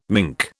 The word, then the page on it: minke: British English